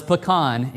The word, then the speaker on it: pecan: And I'm saying it right too, Brother Curry. I know. That was one of the first lessons I learned here, moving here from Illinois. It was pecan, and I was quickly challenged to change my mind on that one.